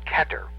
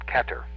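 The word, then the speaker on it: Kether: The first sephira is called Keter, and I'm going to tell you how to spell it, but don't write it in yet.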